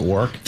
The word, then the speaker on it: Warwick: It's because he went to the University of Warwick. – Oh. – I pronounce it that way just to aggravate. – Is it Warwick? – They quote, Warwick.